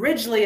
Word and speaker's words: Ridgley: B-R-E-N-D-A. And Ridgley is spelled R-I-D-G-L-E-Y.